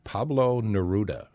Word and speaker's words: Pablo Neruda: The number one was the Latin American poet, Pablo Neruda.